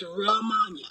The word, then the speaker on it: Romania: I changed my Facebook name so people could pronounce it right. It's not even spelled that way. It's spelled like Romania. – It is kind of. – Yes. – You've got to remember Mon.